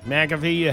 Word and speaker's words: McAfee: No word yet from Aaron Rodgers, but Pat McAfee, how do you say that? – McAfee has apologized for what Rodgers said about Jimmy Kimmel on the Pat McAfee show the other day.